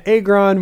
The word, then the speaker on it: Agron: I'm sorry, it's Diana Agron, people. – Apparently. – I don't know if you've seen her Nintendo DS commercial, but she pronounces her name Diana Agron.